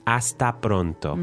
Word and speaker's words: hasta pronto: Hasta pronto. – Mm-hmm. – Okay, and you still don't pronounce the H.